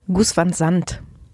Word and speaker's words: Gus van Sant: Gus van Sant.